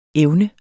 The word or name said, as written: evne